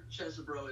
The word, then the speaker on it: Chesebro: And I'm sure Chesebro is the way to pronounce it, but anybody who decides to represent Trump, I'd like to pronounce his name Cheesy Bro going forward.